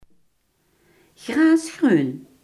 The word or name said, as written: grasgroen